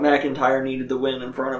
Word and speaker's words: McIntyre: You got to emphasize each syllable. – McIntyre.